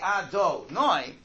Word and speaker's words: Adonai: It is spelled this way, Yud Kei Vav Kei, but it's pronounced this way. Now, when you say that name, when you say Adonai, you have to be thinking of two things.